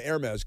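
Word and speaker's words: Hermès: It's spelled Hermes, but they say Hermes because it's French.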